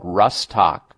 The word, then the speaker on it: Rhus tox: One is called Rustoc, and that's spelled R-H-U-S and then T-O-X, two separate words. I'm giving you the abbreviated form of the homeopathic ingredient, Rus, R-H-U-S, T-O-X.